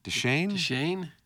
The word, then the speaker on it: Deschaine: And do you say Deschaine? – Deschaine. Can you spell it, please? – Sure. D-E-S-C-H-A-I-N-E. – A-I-N-E.